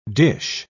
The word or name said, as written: dish